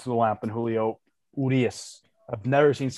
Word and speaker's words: Urias: What's how you pronounce his last name? Urias? – No. It's not about his last name. It's just Urias.